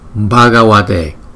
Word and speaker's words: Bhagavate: Bhagavate, Bhagavate, Bhagavate. Chữ V, chữ V này nè, chúng ta đọc là wa, wa. Giống như chữ W vậy đó.